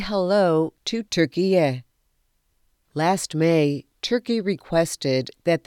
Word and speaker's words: Türkiye: Türkiye is how the country's name is pronounced in Turkish.